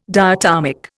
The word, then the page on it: diatomic: Indian